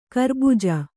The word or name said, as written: ಖರಬುಜ